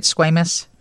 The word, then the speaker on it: squamous: Did I say that right, squamous? – Yeah, you said that right.